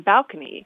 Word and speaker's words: balcony: because I know because the word had been borrowed in from Italian, and in Italian the stress is on the second syllable, so it would be balcony. But in English, we often like to have the stress on the first syllable, so it became balcony.